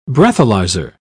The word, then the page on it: breathalyzer: British English